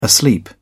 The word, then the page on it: asleep: British English